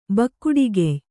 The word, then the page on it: ಬಕ್ಕುಡಿಗೆಯ್: kannada